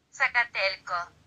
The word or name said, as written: Zacatelco